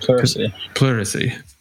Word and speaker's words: pleurisy: Pleurisy.